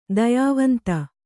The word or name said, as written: ದಯಾವಂತೆ